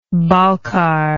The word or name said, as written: balkar